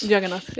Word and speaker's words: Jagannath: Eh, jag, när jag läste din novell Jagannath, är det korrekt uttalat? Jagannath, ja.